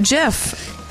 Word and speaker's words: GIF: I did my research on this because I'm telling you, it's pronounced Jif.